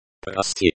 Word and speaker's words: Прости: Прости, the O in pro is pronounced like the O in go. The sti is pronounced like the sti in steely.